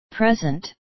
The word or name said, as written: present